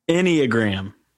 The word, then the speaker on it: Enneagram: Enneagram. – Enneagram? – Yeah. Yeah. Like the word any at the beginning. Like not just one gram, it's enneagram.